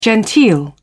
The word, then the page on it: genteel: British English